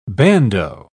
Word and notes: bandeau: British English

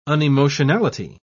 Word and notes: unemotionality: British English

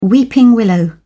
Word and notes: weeping willow: British English

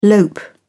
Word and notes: lope: British English

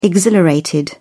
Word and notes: exhilarated: British English

